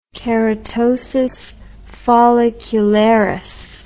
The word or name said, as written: keratosis follicularis